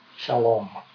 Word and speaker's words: shalom: 希伯来人用了一个非常丰富的字来形容它，这个字的发音叫做 shalom，翻成中文叫做平安，表示里面充满了和谐、和睦、欢欣、愉悦、丰盛，一无所缺，一无所虑，你所做的事情都顺利。